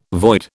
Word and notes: void: British English

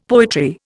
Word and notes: poetry: Indian

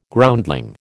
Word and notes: Groundling: American English

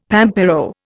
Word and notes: pampero: Indian

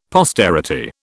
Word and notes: posterity: British English

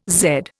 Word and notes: zed: Indian

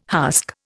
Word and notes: husk: Indian

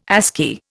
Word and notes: ASCII: Indian

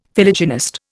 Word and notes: Philogynist: Indian

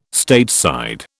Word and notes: Stateside: British English

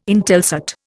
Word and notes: Intelsat: Indian

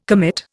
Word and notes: commit: Indian

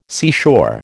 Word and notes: seashore: American English

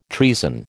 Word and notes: treason: American English

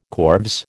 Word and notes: corves: American English

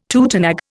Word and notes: tutenag: Indian

